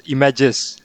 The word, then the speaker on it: eMagix: En is werkzaam als software delivery manager bij eMagix. Zeg ik het goed? – Nou, niet helemaal. Het is eMagix.